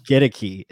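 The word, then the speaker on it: Goedeke: Gedeki is the right way to say it, as we've been pronouncing it wrong.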